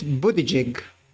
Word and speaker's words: Buttigieg: Transportation Secretary Pete Buttigieg. I think I pronounced it right. – No, you pronounced that's fine.